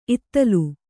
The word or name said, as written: ಇತ್ತಲ್